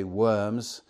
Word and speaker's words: Worms: But at the Diet of Worms, Worms being a town in Germany, we spell it in English W-O-R-M-S, we would say Worms, but in German it was Worms,